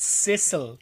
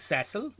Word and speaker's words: Cecil: But it's Sissel. They it's like Sissel. I just watched it today, and it's Sissel. It's like I've never heard that name, C E C I L, pronounced that way.